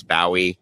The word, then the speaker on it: Bowie: That is the Bowie is the proper Scottish pronunciation. I get a lot of flack for it, but I swear to God, it's Bowie, and I'm sticking to it.